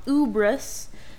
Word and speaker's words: Ubris: So the continent that I created is called Ubris, pronounced kind of like hubris, but without the H.